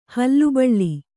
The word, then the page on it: ಹುಲಿಬಲೆ: Kannada